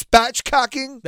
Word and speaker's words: spatchcocking: And now everybody's doing this thing called, I don't even know if I'm saying it right, because it sounds like it should be an FCC violation. – Yep. – But I believe it's spatchcocking. – That's correct.